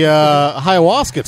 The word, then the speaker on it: ayahuasca: I'm missing the ayahuasca talk. – It's a silent H.